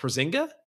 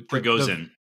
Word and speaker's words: Prigozhin: Prozinga? Prozingan? The Russian coup. – Prigozhin.